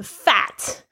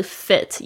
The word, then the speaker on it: fit: 好，接下来是第五句第六句，刚才已经讲了。第七句 I love getting fit，那大家特别注意一下你们 fit 的发音哦。有些同学如果念成 fat 就会变得很奇怪。 fat 是胖哦， fit 是苗条，为什么这两个字的发音又那么像，真的好讨厌哦。我就真的有听到我的学生就念成 fat，不是 fat 是 fit，也不是 feet，不是脚，而是这个短音的这个 it，它很像那种就是在行军的时候会说 it 二三四那个 it。